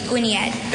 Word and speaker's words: Gwyniad: Gwinniad. – Gwinniad. G W Y N I A D. Gwinniad.